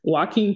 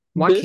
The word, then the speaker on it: Joaquin: Joaquin Phoenix. – Joaquin. – This shocked. Yeah, that's how you say his name.